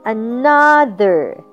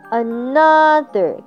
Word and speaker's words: another: 然后我们再看另外一个字， another，这个字呢， made up by 3-7 alphabets，可是它在发音的地方有三段， another，高音在第二段。